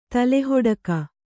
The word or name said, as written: ತಲೆಹಿಡುಕಿ